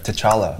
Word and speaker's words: T'Challa: Wait, the T is silent. Wait, is it? – No, it's very much there. – T'Challa.